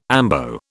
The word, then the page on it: ambo: British English